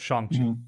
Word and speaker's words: Shang-Chi: So it's Shang-Chi.